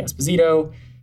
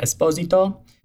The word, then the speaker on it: Esposito: Technically, we should all be saying Giancarlo Esposito. It's my Italian best attempt at my proper Italian pronunciation, as Giancarlo is truly Italian. Most people kind of Americanize his last name and say Esposito. But technically, it is Giancarlo Esposito.